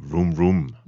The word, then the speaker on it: Room Room: Aktuelt lige for øjeblikket med albumet Room Room. – Yes. – Udtaler jeg det rigtigt? – Eller? Det er fuldstændig rigtigt.